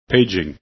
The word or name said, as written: paging